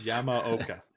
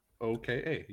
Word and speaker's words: Yamaoka: It's pronounced kind of the way it's spelled. Yamaoka. Yamaoka.